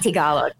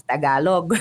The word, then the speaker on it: Tagalog: So it's not Tagalog, it's Tagalog.